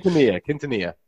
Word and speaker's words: Quintanilla: I think there was a tweet out by Carl, what's his name, from CNBC, Quintanilla. Is that how you pronounce it? I'm not sure how to pronounce his name. – Quintanilla.